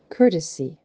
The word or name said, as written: Courtesy